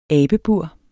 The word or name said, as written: abebur